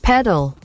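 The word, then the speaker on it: Petal: Klingt jetzt wie dieser Tennissport, aber es wird mit T geschrieben, aber gesprochen eben mit D. Ich habe hier extra bei Google nochmal die Aussprache angeschmissen. – Paddle.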